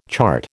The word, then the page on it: chart: American English